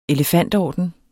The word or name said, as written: elefantorden